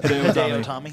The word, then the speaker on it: Hideo Itami: Hideo Itami.